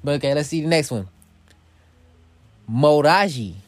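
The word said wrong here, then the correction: Modjadji: And I'm probably saying that wrong too, because trust me, I'm telling you, I don't know how to pronounce a lot of these, but it's Modjiji, okay?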